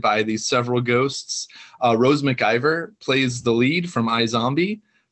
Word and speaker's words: McIver: Last name is MacGyver? – McIver. Oh. Close. Close.